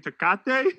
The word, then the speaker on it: Tecate: G and I were at the bar one time, and I was like, yo, G, give me this drink right there, Tecate. And he looked at me like, Tecate? I was like, yeah, right there. He's like, you mean Tecate? So yeah, I'm not good with my Mexican beer and silent J's.